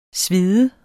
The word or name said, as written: sved